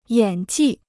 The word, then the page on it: 演技: yǎn jì